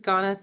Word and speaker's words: Ganna: My name is Ganna Tymko.